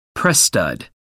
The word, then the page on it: press stud: American English